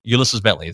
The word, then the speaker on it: Ulysses Bentley: That he is, and Bentley is going to get his, or Ulysses Bentley.